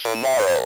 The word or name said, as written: tomorrow